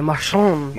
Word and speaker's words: Marchand: You made it sound real fancy. Marchand.